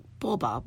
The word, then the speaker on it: Coba: It's actually pronounced Boba, but it's spelled Coba, so C-O-B-A. And Boba is the way it's pronounced in the Mayan language, one of the many dialects.